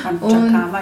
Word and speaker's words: Panchakarma: Habe ich es überhaupt richtig ausgesprochen? – Panchakarma ist, ja, genau.